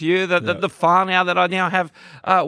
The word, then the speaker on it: whānau: And you said to me, I was I was now part of the the, the whānau. Um, did I say that right? – Yep, you're part of the HP whānau for sure.